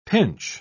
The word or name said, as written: pinch